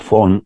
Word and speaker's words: von: Esa expresión se le debe a un jurista alemán, Anselm, termina en M, como Anselmo sin la O, Anselm von, con V, la V en alemán suena como nuestra F, Anselm von Feuerbach.